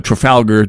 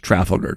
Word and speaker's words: Trafalgar: It was the Trafalgar poll, Trafalgar to most Americans. It's pronounced Trafalgar, go figure.